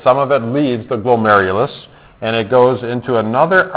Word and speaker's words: glomerulus: All right, so we got to practice pronouncing this because you might have to spell this sometime. So this is glomerulus. Glomerulus. All right, so let's all say it together. It's glomerulus. And if you put it all together, it is glomerulus.